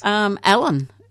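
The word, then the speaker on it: Allan: Allen.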